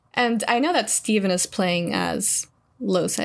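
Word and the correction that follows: Lohse: Is that how you pronounce it? Lose. – I think it's Losa. I think it's a Losa. – Soft. – Yeah, Losa. – Yeah, Losa. – Losa. – It's French, you know. Yeah. If we had Ren on this podcast, I'm sure she could confirm for us that it's French.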